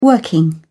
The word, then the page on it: working: British English